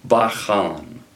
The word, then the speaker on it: bachan: The Hebrew word for test, bachan. Bachan, let me spell that. B-A-C-H-A-N.